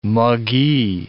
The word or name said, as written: muggee